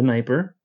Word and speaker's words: Dnieper: This city becomes the most important during this time because of its location along the river, and that river, the Dnieper, I got that pronunciation right, I'm so proud of myself, leads into the Black Sea.